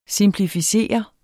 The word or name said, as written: SIMPLIFICERES